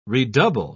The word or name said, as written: redouble